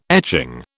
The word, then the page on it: etching: British English